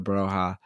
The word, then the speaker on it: Broja: Scored a few goals as well, but so Broja is, I feel like I never say his name right, but I say Armando Broja. But that's just isn't it another way that you say it? – You say it fine.